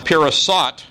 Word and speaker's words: pirasat: The first occurrence is at the beginning of the verse, and it's pronounced peres, half mina. Its second occurrence is a passive verb written in the PL or intensive stem where it's pronounced pirasat and translated divided. And then the third occurrence is the last word in the sentence, paras, which means Persians.